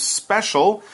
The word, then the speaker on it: special: And here we have this adjective special, S-P-E-C-I-A-L, S-P-E-C-I-A-L, but we pronounce it special, that C and I, it kind of comes out with an S-H kind of sound, mm-hmm.